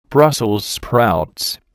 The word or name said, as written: Brussels sprouts